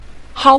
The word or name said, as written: hao5